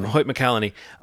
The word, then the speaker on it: McCallany: McCallany? I think it's McCallany. Is that his name? – Hoyt McCallany.